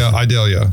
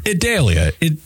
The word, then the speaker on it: Idalia: Idalia. – Yeah. – I think that sounds fair. – Idalia. Idalia. – It's not like Adele. It's not like ideal.